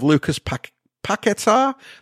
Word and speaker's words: Lucas Paqueta: Manchester City Football Club are potentially trying to sign a footballer, a Brazilian no less, by the name of Lucas Paqueta. That's how you pronounce it.